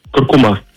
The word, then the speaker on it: куркума: Несмотря на то, что большинство произносит данное слово с ударением на последний слог, акцент в нем все же стоит на втором. Правильно говорить куркума, представляете?